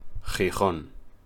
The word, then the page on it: Gijón: Spanish